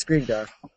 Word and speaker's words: Skrigdar: And I'm also on Steam, as my screen name would be Skrigdar. Don't ask me how to spell it. No one can. – Is that how you pronounce it? – Yes, Skrigdar. – I've always pronounced it as Shriegdar.